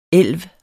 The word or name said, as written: ELVS